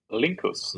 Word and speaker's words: Linckus: Linckus.